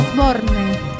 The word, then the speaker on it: Osborne: Este es Oborné. – Bertino Osborne. – Muy bien, gracias. Gracias. ¿Cómo lo pronuncia? A ver, de vuelta. – Bertino Osborne. Cuando empecé a escuchar Americano, pensé con estos temas.